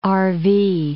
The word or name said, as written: RV